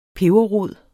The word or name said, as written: peberrod